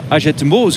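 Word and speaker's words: Hagetmau: Na de start er in Agetmaux, zo moet je het precies uitspreken.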